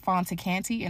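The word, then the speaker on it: Fanta Kante: You can also follow me on Facebook at Fonta Kante, and that is spelled F-A-N-T-A, last name K-A-N-T-E.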